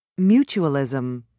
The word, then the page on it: mutualism: American English